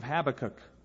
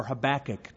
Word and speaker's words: Habakkuk: It's written in the book of Habakkuk or Habakkuk, depending on what part of the country you're from, how you want to pronounce it.